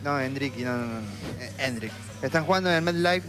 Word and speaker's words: Endrick: Eso descarta la entrada de Endriki. – Puede ser. – Yo quería ver a Endriki. – No me estás cerrando del todo la pronunciación. – Endrick, ok.